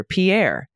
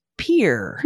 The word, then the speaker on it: Pierre: I would have pronounced it like this, like our then prime minister, Pierre. But in Nebraska, it's pronounced Pierre.